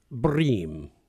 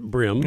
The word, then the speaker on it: bream: You know, the English do pronounce it bream. – Do they? – But we pronounce it brim.